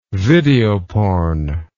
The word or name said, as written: videoporn